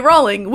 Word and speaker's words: Rowling: So says Harry Potter author J.K. Rowling, which, incidentally, rhymes with bowling, about the pronunciation of Loach Voldemort, the blockbuster series creepy villain.